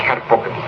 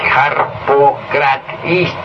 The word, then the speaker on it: Harpócrates: El nombre de Harpócrates es Harpócrates, pero como mantra es Harpocratis.